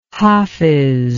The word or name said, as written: hafiz